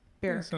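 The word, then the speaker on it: Baruch: So after being discharged from the Israeli military, Adam moved back to the States and attended, again, I hope I'm pronouncing this right, Zicklin School of Business at Barak College in New York City? B-A-R-U-A-C-H. Barak. I don't right. – know. – Anyhow. Sounds right.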